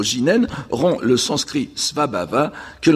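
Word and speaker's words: jinen (自然): Donc, prononcer au Japon « jinen » dans un contexte bouddhique, parce que le japonais fait très clairement la différence entre le shizen nature actuel et le shizen bouddhique, qui est alors prononcé « jinen », je n'insiste pas là-dessus.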